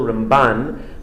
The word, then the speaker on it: Ramban: And you should distinguish the pronunciation. Usually we accent the first syllable, Rambam, and then in the Ramban, it's the second syllable, Ramban.